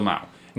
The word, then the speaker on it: Mao: We have a fight between Carter Wong and Angela Mo versus all the students at first. Did I say it wrong? Angela Mao. Angela Mao. – There you go. Mo. – Yeah. I'm probably thinking of the Cantonese pronunciation.